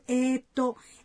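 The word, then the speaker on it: ええと: Na pronúncia de a vogal E tem um som prolongado. – Eu sempre uso eto.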